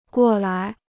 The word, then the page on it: 过来: guò lai